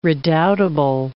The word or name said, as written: redoubtable